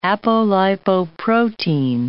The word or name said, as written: apolipoprotein